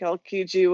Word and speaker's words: Aokiji: Also, I want to say that I also think Aokiji would, or how do you pronounce his name? – Yeah, you said it right.